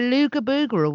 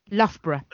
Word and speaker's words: Loughborough: Lugabugra was. – Go Loughborough. Lugabugra. – Loughborough.